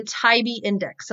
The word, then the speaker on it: TIOBE: And I'm going to encourage you to go to and look at the Tybee Index.